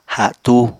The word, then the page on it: hatú: apatani